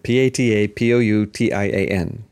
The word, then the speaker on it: Patapoutian: And then the Nobel Prize to Physiology or Medicine was jointly given to David Julius and Artem Patapushian. – Patapushian. – I'm going to say it. Patapoutian. That's how I pronounce it in American English anyway.